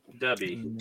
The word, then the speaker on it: Dubby: Dubby. D-U-B-B. It's right in the middle of our screen right there. Yeah. I put the logo on it. – Like hubby, but with a D.